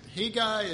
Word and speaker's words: Hegai: The reason why I'm pronouncing it Hegai is I got sick of pronouncing it 87 different ways, and I went and I got Alexander Scorby's verbal reading. And this is what I came away with.